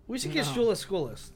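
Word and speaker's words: Schuylkill: Speaking of school list, yours, Christian, the other day kept pronouncing, what'd you say? You called him Skykill. Aaron's school list, he just kept saying Skykill.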